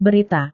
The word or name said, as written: berita